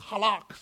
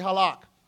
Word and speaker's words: halak: The term for walk is a word that's pronounced halak. Somebody say halak. Now you got to see, you got to sound like a real Israelite. Say halak. Halak.